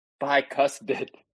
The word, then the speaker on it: bicuspid: Camp Biscos Pids. For those who don't know, a biscus pid is a premolar, transitional tooth between a canine and a molar. Of course, we usually it's usually pronounced bicuspid.